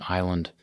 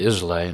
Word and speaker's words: Islay: And my wife and I were on Isla, which is an island on the west coast, and it's spelled Islay. And some people say Islay, and this is your educational aside for today. You pronounce it Isla.